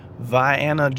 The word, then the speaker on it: Vianna: All right, we're off to the great small town of Vianna, Georgia. It's spelled like Vienna, and I should have played my GPS when it pronounced it that way, but that is often mistaken as the capital of the country of Austria.